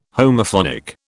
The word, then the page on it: Homophonic: British English